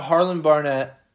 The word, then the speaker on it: Harlon Barnett: So now Harlon Barnett, am I saying that right? – Yes.